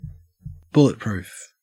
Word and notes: bulletproof: Australian English

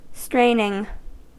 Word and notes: straining: American English

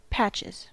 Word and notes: patches: American English

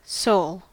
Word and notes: soul: American English

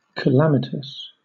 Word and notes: calamitous: British English

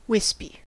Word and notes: wispy: American English

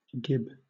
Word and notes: gib: British English

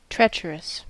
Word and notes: treacherous: American English

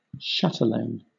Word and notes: chatelaine: British English

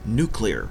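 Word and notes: nuclear: Canadian English

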